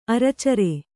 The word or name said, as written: ಅರಚರೆ